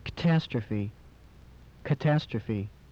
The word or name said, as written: catastrophe